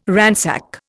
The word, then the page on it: ransack: Indian